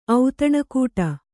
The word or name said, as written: ಔತಣಕೂಟ